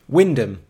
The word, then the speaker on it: Wymondham: Amy, how do you say this place name? – Oh my God. Wymondham? Wymondham? – Wyndham. And there aren't any in Wyndham anyway. She says... – I've heard of Wyndham.